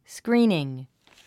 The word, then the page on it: screening: skríːniŋ; スクリーニン